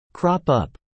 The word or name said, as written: crop up